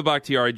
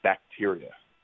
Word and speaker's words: Bakhtiari: The absolute worst wasn't even close. It was, and again, this was soccer. I remember they said David Bacteria, and I was like, that's not even close.